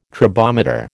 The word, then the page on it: tribometer: American English